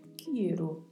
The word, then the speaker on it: quiero: Cuidado para não deixar de pronunciar a letra I. – Pronuncie corretamente a letra E e a letra O também mais fechadas, então pronunciamos quiero. Agradecer-te.